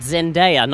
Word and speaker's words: Zendaya: Zendaya, not Zendaya, Zendaya or Cynthia.